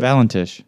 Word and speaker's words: Valentich: Valentich. I don't know why I'm asking you. But Valentich. – Valentich? – Wait, V-A-L. – Like Valentine, but after the I, it's just C-H.